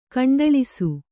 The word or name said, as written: ಗುಂಡಾಳಿಸು